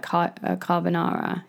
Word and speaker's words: carbonara: But, like, have you ever seen, they have, like, the big, they do, like, carbonara. Is it carbonara? Is that how you say it? – Carbonara, yeah.